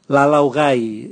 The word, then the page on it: lalau gai: Raga